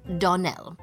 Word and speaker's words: Donnell: Donnell. Accent on last syllable.